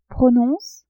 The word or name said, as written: Prononce !